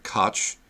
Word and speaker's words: Koch: Every other person I've ever met pronounces that word Koch, and that's what we're going to call them.